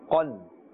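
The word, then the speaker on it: qan (قاف): Kan and qan. Can you hear the difference? Can you tell me why we are pronouncing with the full mouth the qaf? Because it's part of the huruf musta'adiyah. And therefore it must be said with a thick tone, a thick voice, a thick sound, a full mouth.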